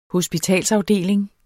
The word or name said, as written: hospitalsafdeling